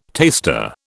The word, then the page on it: taster: British English